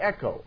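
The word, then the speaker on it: echo (ἔχω): E-C or E-K-H-O, pronounced E-C-H-O, echo.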